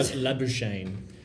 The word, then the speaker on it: Labuschagne: They have brought in Marcus Lasagna. – Yeah. – What? Labuschagne.